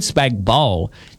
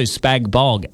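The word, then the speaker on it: spag bol: Spag bowl, the G is silent.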